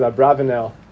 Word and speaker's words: Abarbanel: There's an incredible question of the Abarbanel on the, some pronounce it Abravanel, on the Haggadah.